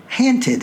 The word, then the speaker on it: haunted: For example, in the Richmond of Poe's time, and still in many areas of Virginia, the phrase haunted house is pronounced ha'nted house.